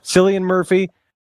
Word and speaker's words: Cillian Murphy: Cillian Murphy.